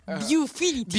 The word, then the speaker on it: Viewfinity: Viewfinity. Viewfinity. – Samsung cada vez tiene un nombre tan raro, ya no sé. yo, lo, presento, ¿eh? – ¡Ah, su máquina! – Samsung Viewfinity S9 5K.